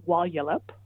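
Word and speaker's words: Walyalup: So it's Wallyalup, and the first syllable is the one that gets the stress.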